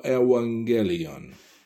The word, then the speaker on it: Evangelion: Ng, the two gammas, one after the other, you pronounce ng. So, Evangelion.